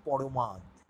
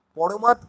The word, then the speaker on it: প্রমাদ: এই স্বাদ সাধিতে, এই স্বাদ পূরণ করতে ঘটে যদি পরমাদ। পরমাদ কথাটা আসল উচ্চারণ হচ্ছে প্রমাদ, প্রমাদ মানে বিপদ, কিন্তু কবিতায় কিছু কিছু শব্দ ছন্দ মেলানোর জন্যে একটু পাল্টিয়ে লেখা হয়।